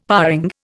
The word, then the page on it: paring: Indian